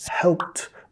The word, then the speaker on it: helped: Helped. Pasado participio. Helped. Igual, estas dos, nota que el ED se pronuncia como T.